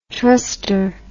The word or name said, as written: truster